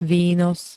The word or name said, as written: výnos